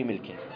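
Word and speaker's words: مِلك: الأفضل يا مشايخ في عبارة الفقهاء في مسألة الملك وملكه أن تنطق بكسر الميم. طيب، أن يقال ملك، وأن يقال ملكه، وأن يقال ملكه، هذا هو الأفضل.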